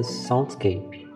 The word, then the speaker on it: soundscape: Paisagem sonora é um conceito de origem na palavra inglesa soundscape. Eu acho que essa é a pronúncia correta